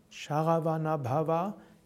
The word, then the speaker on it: Sharavanabhava: Das Mantra besteht hauptsächlich auf kurzen Silben. Es ist Om ist lang, Sharavanabhava ist kurz, aber Bhavaya ist wieder lang, und dann kurz. Also Sharavana, alles kurz und relativ zügig und dann Bhavaya, Va ist lang, Ja kurz, Namaha kurz.